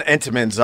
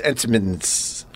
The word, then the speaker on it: Entenmann's: There's only one Entenmann's I get. And you're saying it wrong. It's Entenmann's.